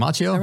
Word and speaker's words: Macchio: Macchio? – Yes, yeah, Macchio.